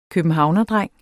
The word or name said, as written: københavnerdreng